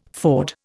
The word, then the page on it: ford: Indian